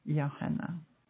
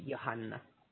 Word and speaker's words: Johanna: Once you pronounce it in a very Finnish, proper, truly Finnish way, then it's Johanna. – Johanna. – The emphasis in the second bit in the H-A, Johanna.